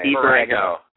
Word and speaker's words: Dee Borrego: Dee Borrego.